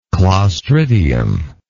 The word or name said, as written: clostridian